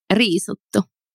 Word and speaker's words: Riisuttu: How did you say it? – Yeah. Riisuttu. – Riisuttu. Like risotto. Risotto, like the Italian.